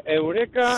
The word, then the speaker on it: Eureka: Se escribe Eureka, sí. Y se pronuncia Eureka.